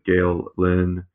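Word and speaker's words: Gael Linn: Gael Lynn. I'm sure I'm mispronouncing that. I apologize. – No, you're not. That's perfectly pronounced.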